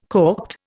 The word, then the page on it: corked: Indian